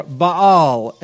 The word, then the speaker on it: Baal: Baal worship, or Baal, as some pronounce it.